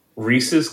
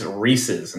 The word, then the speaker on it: Reese's: We did a massive, massive, massive takeover, not of a coaster to start because nothing was open, but Reese's Cup Fusion, and yes, Reese's, not Reese's. Reese's Cup Fusion. Reese's, Marcus. Reese's.